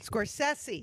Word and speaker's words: Scorsese: I want to say this the right way because I always mispronounce his name, Martin Scorsese. – Scorsese, yes, get it right.